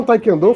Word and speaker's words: taekwondo: É taekwondo a pronúncia correta da arte que a underline faz, e não Taekwondo. Tai, é, que o pessoal fala não, Taekwondo.